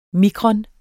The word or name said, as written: mikron